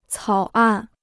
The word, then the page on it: 草案: cǎo àn